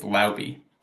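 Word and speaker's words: Laube: It's, I looked it up, by the way. It's Lauby. That's how you say it.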